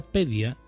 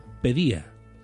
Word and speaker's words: paideia: Al mismo tiempo, la tragedia servía de aprendizaje, denominando en términos clásicos como paideia. Se pronuncia pedia de ahí las palabras como logopedia. Es decir, paideia sería fonéticamente y se pronuncia pedía.